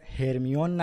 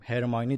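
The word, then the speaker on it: Hermione: علاوه بر هاگوارتس کلی دیگه از اسامی کتاب هم تغییر کرده، تلفظا دقیقا مثل نسخه بریتانیاییه، یعنی ما دیگه هرمیون نداریم، هرماینی داریم، یا دورسلی نداریم، درزلی داریم و کلی اسامی دیگه.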